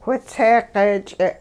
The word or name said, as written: whitsee-q'eech'e'